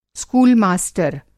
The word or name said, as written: स्कूल मास्टर